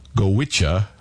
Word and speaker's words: Goitse: Let's close out this hour of music here with a traditional Irish quintet pronounced Gowitcha, but spelled G-O-I-T-S-E.